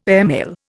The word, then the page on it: airmail: Indian